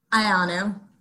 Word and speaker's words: Ioannou: Ioannou.